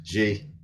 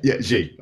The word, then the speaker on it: Xi: For Xi, am I pronouncing that right? Xi? Xi, okay. I pronounced it wrong the other day. Like, no, it's like this.